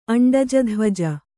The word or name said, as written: ಅಂಡಜಧ್ವಜ